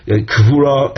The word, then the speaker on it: kvura: kvura. There it's a kuf and a chof, but the same initial sound. With regard to both words, the way we pronounce it at least, kvura and ksuba.